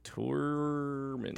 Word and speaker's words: Tormund: Tormund? – T-O-R-M-U-N. – Tormund.